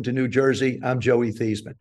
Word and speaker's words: Theismann: I said, Granny, they want to change the pronunciation of our last name from Theismann to Theismann. What are your thoughts? And she said, well, I tell you this. She says, actually, the correct pronunciation is Theismann, and they're closer than what we have now.